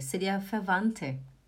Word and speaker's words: Verwandte: Falando em maneira correta e vocabulário de família, como é a pronúncia de Verwandte? Favante, seria favante, Esse DT ali. Verwandte. Somente favante, Tá bom?